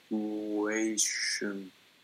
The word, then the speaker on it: situation: So what I recommend, how students can figure this out, is try stressing every syllable, right? And see which one sounds best. – Situation. I don't know. – Okay, so let's try. Let's try the first syllable. Situation. Situation. Situation. Situation. – Yeah. – So which one is correct? – The third. – Yeah, the third. – So situation. – Yeah. Asian, yeah.